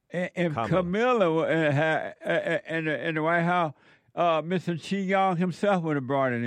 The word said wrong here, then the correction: Xi Jinping: Xi Jinping.